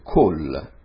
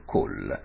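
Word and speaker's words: col (כל): Attenzione a questa adesso, malgrado sia scritta con la A sotto, si legge col.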